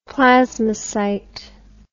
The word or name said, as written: plasmocyte